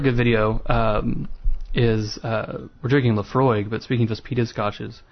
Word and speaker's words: Laphroaig: And so we just Google it, and a YouTube video comes up, and it's just a guy going, Laphroaig. – It's like 3 seconds long, and then it right. – ends. – Laphroaig.